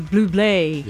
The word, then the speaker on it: Bublé: Bublé.